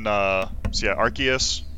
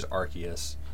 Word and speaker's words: Arceus: Yeah, and then Arceus, which is the correct pronunciation, so I call it Arceus. – I was going to say, I love that you said it both ways in the span of 20 seconds. – Yep. – But if anyone needs proof, the video specifically named Arceus. – It is Arceus. – Yeah, that's the Pokemon company's official pronunciation of it, so sorry, nerds.